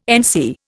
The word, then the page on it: NC: Indian